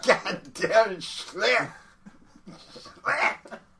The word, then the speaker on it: Schlitt: Schlitt. S-C-H-L-I-T-T.